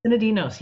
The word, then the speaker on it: Sinadinos: The Essential Guide to Western Botanical Medicine by this lady here, Christa Sinadinos. Am I saying that right? – Sinadinos.